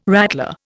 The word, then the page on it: rattler: Indian